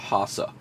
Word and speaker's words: Hassa: but in Guyana it's called Hassa. – Do you know how that is spelled? – H-A-S-S-A or H-A-S-S-A. – Something like that? – H-A-S-S-A, Hassa fish.